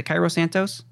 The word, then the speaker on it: Cairo Santos: Because we've seen Cairo Santos, who – is it Cairo Santos? Is that – am I saying that right? – Yeah.